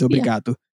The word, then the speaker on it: obrigado: The right way to say it is obrigado.